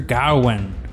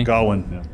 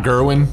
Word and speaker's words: Gawain: The Green Knight tells the story of Sir Gawain, King Arthur's I think I pronounced that correctly. – Gawain. – Yeah. – Gawain, not Gerwin.